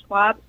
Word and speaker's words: Schwab: You can see my author page on our website there, and then I'm also on Twitter at Nikki, N-I-K-K-I, and Schwab spelled like Charles Schwab.